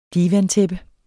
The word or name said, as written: divantæppe